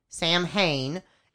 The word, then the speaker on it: Samhain: Now that is spelled S-A-M-H-A-I-N. – Samhain. – Samhain. S-A-M-H-A-I-N, if you'd like to look it up, but it's pronounced. – Irish word. – It's pronounced Samhain.